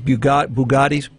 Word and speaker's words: Bugatti: Bugattis. How do you say that? Bugatti. That's close enough.